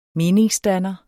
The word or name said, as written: meningsdanner